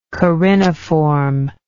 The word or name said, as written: coryneform